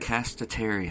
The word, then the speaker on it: Podcastitarian: Podcastitarian. – Podcastitarian. – Yes. So think of like a pescatarian, but it's podcast. Podcastitarian.